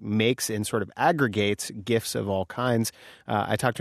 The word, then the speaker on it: GIFs: The future of advertising could be, hard G, GIFs. – We hear you, Kai Ryssdal, and we have chosen to pronounce the word as GIFs.